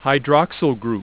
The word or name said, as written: hydroxyl group